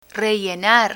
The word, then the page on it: rellenar: Spanish